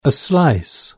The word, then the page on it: a slice: British English